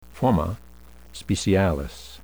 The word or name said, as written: forma specialis